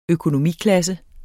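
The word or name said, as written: økonomiklasse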